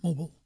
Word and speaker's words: Mobile: That's how you say it down there, Mobile.